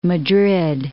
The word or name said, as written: Madrid